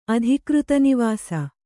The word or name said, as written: ಅಧಿಕೃತನಿವಾಸ